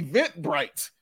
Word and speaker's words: Eventbrite: Is it the Evite Bright? Am I saying that right? – Eventbrite. – Thank you very much. The Eventbrite.